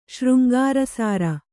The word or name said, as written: ಶೃಂಗಾರಸಾರ